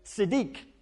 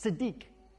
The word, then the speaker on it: tzedek: And then the second word here, in the place of justice, that word is the word tzedek. There's a T and an S next to each other, which make it quite hard to pronounce. Tzedek.